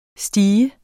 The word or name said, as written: steg